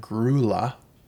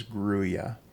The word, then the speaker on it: grulla: Because I know that a lot of Canadians and others pronounce the word Grulla, G R U L L A, but in fact, the proper pronunciation is Gruya.